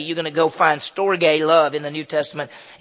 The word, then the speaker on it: storge: That's how it's pronounced. It's storge.